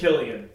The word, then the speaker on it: Cillian: It's Cillian. It is Cillian. – Yeah. – I swear I've heard him say his name as Cillian. That's interesting. Oh. – Like celery.